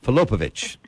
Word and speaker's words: Filipovic: And Jill Filipovic, do I say that right? Filipovic, right? Filipovic. I got it.